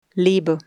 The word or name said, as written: lebe